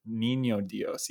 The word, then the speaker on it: Niño Dios: Things like the Control, Wendigo, Niños Dios. Am I saying that right? – Niño Dios.